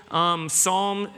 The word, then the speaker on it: Psalm: Psalm spelled P-S-A-L-M, a silent P.